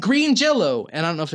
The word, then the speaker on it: Green Jelly: It's spelled Green Jelly, but pronounced Green Jello.